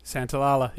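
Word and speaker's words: Santaolalla: Gustavo Santalala? – Santalala, yeah. – That's it. Thank you.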